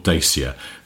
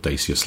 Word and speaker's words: Dacia: Because Dacia is how a Romanian person would say it. But you have to allow for sort of sometimes localised pronunciations.